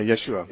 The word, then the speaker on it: Yeshua: Wasn't it Yeshua, or how do you pronounce that? – Yes. Yeah, Yeshua.